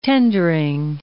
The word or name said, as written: tendering